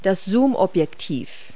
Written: zoomlens

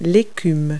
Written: foam

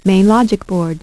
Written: main logic board

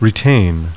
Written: RETAIN

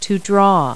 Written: draw

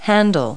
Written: hand tool

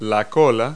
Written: tail

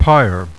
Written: pyre

